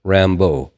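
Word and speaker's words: Rimbaud: There was a French poet, Jim Morrison was fond of his name was, it's R-I-M-B-A-U-D. And that pronunciation in French is Rambo.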